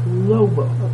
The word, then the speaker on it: Loible: John Lobo. – John Noble? – Lobo. – How do you spell that? – L-O-I-B-L-E. – L-O-I-B-L-E? – Mm-hmm.